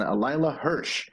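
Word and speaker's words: Leyla Hirsch: The Dr. Britt Baker, DMD, will take on Layla Hirsch. Do we know? – Yeah. You got it. – Okay, Layla. Very good.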